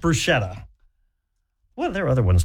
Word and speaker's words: bruschetta: There are other ones too, though, like bruschetta. People say bruschetta because of the way it's spelled. – Bruschetta. But you bruschetta. – Have to say it with an Italian accent.